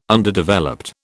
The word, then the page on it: Underdeveloped: British English